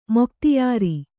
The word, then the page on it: ಮೊಖ್ತ್ಯಾರ: Kannada